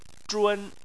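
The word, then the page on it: zhun: tone 1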